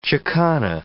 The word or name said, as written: Chicana